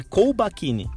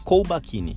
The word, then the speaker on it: Colbacchini: Então, assim, é um L antes do B. Fizeram tanto um alarde para falar do Sobs que não tinha acento quando o Sobs chegou no Inter, né? Tá todo mundo falando errado o nome do cara aí faz três dias e ninguém se ajuda, né? Então é Colbacchini. Isso. Colbacchini. Isso aí.